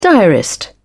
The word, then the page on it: diarist: British English